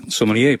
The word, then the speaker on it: sommelier: Sommelier, precies. Ja. Maar er zat nog iets anders wat me toen opviel, is dat het woord sommelier tegenwoordig ook vaak of regelmatig als sommelier wordt uitgesproken. – Echt?